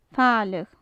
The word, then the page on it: faileadh: gaelic